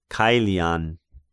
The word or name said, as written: Caelian